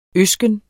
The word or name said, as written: øsken